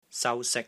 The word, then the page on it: SAU1 SIK1: Cantonese